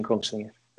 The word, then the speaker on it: Kongsvinger: And indeed, you've been assistant coach in Kongsvinger, or were you the first team coach in Kongsvinger? Please correct me if I don't pronounce it. – Yeah, first team coach in Kongsvinger.